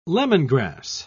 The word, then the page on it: lemongrass: British English